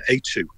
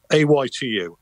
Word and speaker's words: Aitu: I went to a village, and there was they call a ghost an Aitu. That's Aitu, I believe it is. Aytu. Aitu. It's pronounced, and that's what a Polynesian ghost is known as.